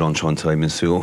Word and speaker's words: Enchanté, Monsieur: Yes, that last one was called Enchanté, Monsieur.